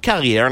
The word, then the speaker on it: Carrière: Richard Carrière, and I say that with the French pronunciation because he's originally from where I am, but right now he's where you are.